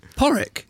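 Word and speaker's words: Pádraig: Spelt strangely, actually. Not spelt how it sounds. – Go on. – It's like, oh no, because I don't want to get it wrong. I think it's spelt P-A-D-R-A-I-G.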